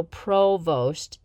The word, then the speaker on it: Provost: Provost. People would pronounce it Prevost or Prevost. I still don't know how Provost is such a hard word to pronounce for some individuals.